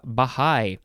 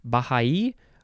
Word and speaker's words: Baha'i: Now we have Baha'i, capital B-A-H-A apostrophe I. I actually know this word. There's a few different ways to pronounce it. Baha'i or Baha'i or, yeah.